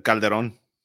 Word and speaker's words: Calderon: Did I pronounce your name right? – Yeah, that's good. – Ed, well, how do you say it? – Calderon.